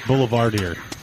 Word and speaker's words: Boulevardier: It's pronounced Boulevardier. That's how we say in America.